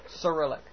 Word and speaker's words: Cyrillic: And so he had one sculpture in North Carolina, I believe at UNC, that was called the Krillic Projector, which was like this cylinder with all these Krillic letters, or I might be saying that wrong, the Russian letters. – Cyrillic. – Cyrillic, yes, the Cyrillic Projector.